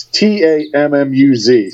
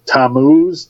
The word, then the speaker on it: Tammuz: Now, what happens is tam- uh, it's T A M M U Z. So Tammuz, Tama, Tamaz, uh-huh. however you pronounce it. I'm going to say Tammuz.